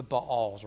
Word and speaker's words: Baal: And the people of Israel did what was evil in the sight of the Lord and served the Baals or Baals. In Hebrew, you pronounce every vowel, every letter, Baal, but Baal's fine.